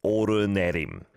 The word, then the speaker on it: 오르내림: O-L-N-L should apparently be pronounced, thanks, Jun, as 오르내림. Yes, it does not say that in our system at all. It's just O-L-N-L. And for English speakers, I guess they're just going to pronounce it like that, O-L-N-L.